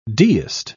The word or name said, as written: deist